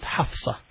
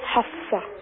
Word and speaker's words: Hafsa: We pronounce it Hafsa. – Hafsa.